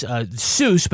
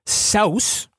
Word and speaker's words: Seuss: So his middle name is Seuss, but it's actually pronounced Souse.